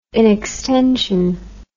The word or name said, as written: inextension